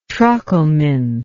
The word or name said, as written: trochelminth